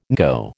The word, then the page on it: NCO: American English